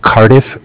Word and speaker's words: Cardiff: Okay, so my name is Zach, spelled Z-A-C-H. With Cardiff, which is spelled C-A-R as in car, D-I-F-F as in different, like Cardiff, Wales in England.